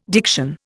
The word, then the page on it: diction: Indian